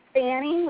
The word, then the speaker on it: Fannie: Fanny with an F like Frank. – Can you spell it? – F-A-N-N-I-E.